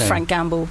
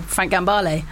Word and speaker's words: Gambale: We just thought Frank Gamble, Frank Gambale. Justin, who I was in the band with, got a record which was Frank Gambale, and he mispronounced it Frank Gamble.